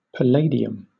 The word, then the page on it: palladium: British English